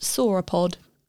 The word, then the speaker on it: sauropod: I'm gonna say it's pronounced sauropod.